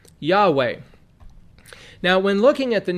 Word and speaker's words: Yahweh: but there's no J in Hebrew. It's a Yod. It's Yah. And so they say Jehovah, but that's a Germanic pronunciation. Yahweh is more correct here.